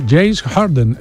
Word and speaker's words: James Harden: Sí, sí, James Harden.